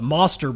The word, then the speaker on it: Mostr: Now the Moster Bridge, M-O-S-T-R, like Noster, but spelt with an M.